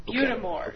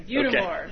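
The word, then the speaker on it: Butte des Morts: And the little lake, which I always mispronounce because I think of it in French. – Butamore. – Butamore. – Okay. It's not French, it's Wisconsin. – Okay, Butamore.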